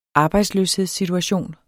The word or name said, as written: arbejdsløshedssituation